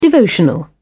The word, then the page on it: devotional: British English